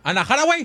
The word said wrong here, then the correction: Anne Hathaway: Anne Hathaway.